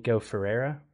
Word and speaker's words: Ferreira: Nico Ferreira. I'm terrible at pronouncing his name. You have to roll your R, and I can't roll my R's.